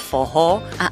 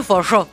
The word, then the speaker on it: forró: Que bueno, en portugués se pronuncia fojó, que la doble R suena como una J, una especie de J para nosotros. – Y en santiagueño, forró.